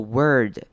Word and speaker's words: word: 好，那 word 也是要注意到这个字很也是很容易念错的，这边 or 要念成 er 的声音，念作 word， word 不要念成 word。